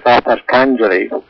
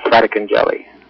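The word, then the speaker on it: Fratarcangeli: That's the usual Canadian pronunciation, I guess. The actual Italian pronunciation is Fratarkinjeli. – In my hometown in Ontario, it was always Fratarkinjeli.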